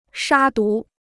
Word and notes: 杀毒: shā dú

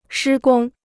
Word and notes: 施工: shī gōng

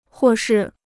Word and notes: 或是: huò shì

